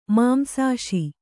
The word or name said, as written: ಮಾಂಸಾಶಿ